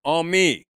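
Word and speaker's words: Ami (עמי): Ami, when you put a long E on the end of it, it's my people.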